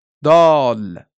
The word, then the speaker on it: دال: و دال این طور تلفظ می شود. دال.